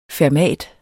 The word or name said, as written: fermat